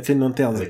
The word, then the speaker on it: Green Lanterns: Et alors je me dis prononce bien Green Lanterns avec, un, S.